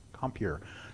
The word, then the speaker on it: Compier: Compierre, C-O-M-P-I-E-R, depending on how French you want to be in pronouncing it.